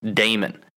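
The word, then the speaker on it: Dahmen: Yes, it's actually Joel Damon, Pat. We've actually had his caddy on the show, and he's actually told you how to pronounce it, and you continue to mispronounce the man's name. It is Damon. It is pronounced Damon.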